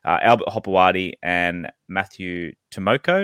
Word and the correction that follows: Timoko: Did I pronounce that right, Harry? – I think it's Timoko. – Timoko.